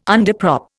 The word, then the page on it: underprop: Indian